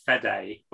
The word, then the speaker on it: Fede: I think it's pronounced Fede, but I think it was Fede because it's Federico. It says it's Federico Alvarez, which is a lot easier to say, to be honest.